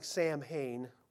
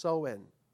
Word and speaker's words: Samhain: It looks like Sam Samhain, but it's called, pronounced Soen.